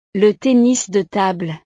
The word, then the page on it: le tennis de table: frances